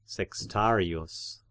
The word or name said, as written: sextarius